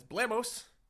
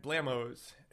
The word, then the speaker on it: Blammos: I mispronounced their name fairly recently as Blamos, and it's in fact Blamos. It rhymes with jam toes, according to Catherine.